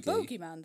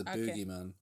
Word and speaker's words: boogeyman: The bogeyman is a thing, right? But this is the boogeyman. – Ah, does he dance? Does he dance into your dreams? No, it's spelled B-O-O-G-E-Y, boogie.